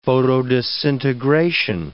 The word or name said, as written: photodisintegration